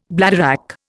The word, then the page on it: bladderwrack: Indian